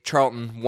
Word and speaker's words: Charlton: Okay, but point stands of this list, Charlton Athletic, and that's how we got to say it. Make Charlton one syllable.